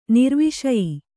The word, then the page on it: ನಿರ್ವಿಷಯ: nirviṣaya